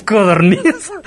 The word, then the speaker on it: codorniz: ¿Sabías que la pronunciación correcta es codorniz? – ¿Es godorniz? – No, es con C de casa, ¿no? – Es con C, ajá, codorniz.